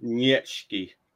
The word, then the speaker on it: Nietzsche: I don't know if it's pronounced like that though. – I've heard it pronounced like that. I'm pretty sure. – Probably Nietzsche. – Nietzsche. – Yeah, because it's German.